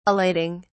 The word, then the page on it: elating: British English